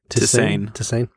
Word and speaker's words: tisane: Tisane.